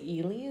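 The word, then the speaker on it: Ehle: We're talking 1995 BBC British series and with Colin Firth and Jennifer, I want to say Ely is how you say her last name.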